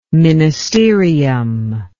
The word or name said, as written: ministerium